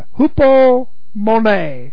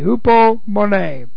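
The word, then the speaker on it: hupomone (ὑπομονή): In the Greek, it is the Greek word, I love it, hupomone, H-U-P-O-M-O-N, long E, hupomone.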